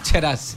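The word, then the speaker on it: Tcherassi: Se escribe T-Che. – T-Che. – Pero se pronuncia Cherassi.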